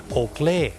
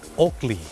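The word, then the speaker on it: Oakley: โอเกลถูกไหมครับคนไทยออกเสียงว่าโอเกลแต่เจ้าของภาษาไม่ได้ออกเสียงว่าโอเกลครับเขาออกเสียงว่าโอกลีโอกลี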